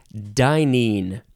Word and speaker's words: dynein: Dynein or dynein. Two syllables or three syllables. D-Y-N-E-I-N. Dynein.